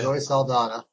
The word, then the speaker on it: Saldana: Zoe Saldana.